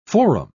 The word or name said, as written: forum